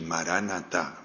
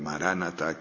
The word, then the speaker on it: Maranata: Marana significa Señor, y ta significa viene. Maranata, todo junto, significa el Señor viene, y Maránata significa ven Señor, ven Señor Jesús, decimos nosotros, ven Señor.